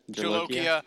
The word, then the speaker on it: Jolokia: They have Bucholikia. Is that right? Did I say it right? – Jolokia.